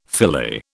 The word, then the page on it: filet: British English